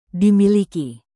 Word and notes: dimiliki: indonesian

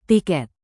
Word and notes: tiket: Indonesian

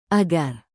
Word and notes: agar: indonesian

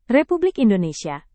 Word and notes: republik indonesia: indonesian

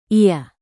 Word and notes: ia: indonesian

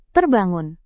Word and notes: terbangun: indonesian

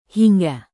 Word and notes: hingga: indonesian

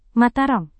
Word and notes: mataram: Indonesian

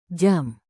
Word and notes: jam: indonesian

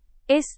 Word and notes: es: indonesian

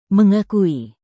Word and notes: mengakui: Indonesian

